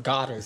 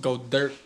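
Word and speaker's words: Goedert: Eh, me gusta decir, vi que los comentaristas decían Dallas Goddard. – Sí, yo también me di cuenta, pero yo lo escuché así en, otra parte. – En el Shake Mate decimos Go Dirt. – Así se pronuncia en realidad.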